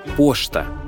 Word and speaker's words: поща: «Поща» – на русском это «почта», а произносится как «пошта», а «пещера» – как «пештера».